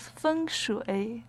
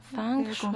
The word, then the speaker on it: Feng Shui: Corrígeme la pronunciación, Sí, decimos Feng Shui. – Feng Shui. – Con tono uno y tercero.